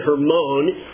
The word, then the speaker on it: Hermon: I believe that's the way you pronounce it, Hermon, H-E-R-M-O-N, there in the distance, covered in the snow, some 9,000 feet high, has a ski resort on it today.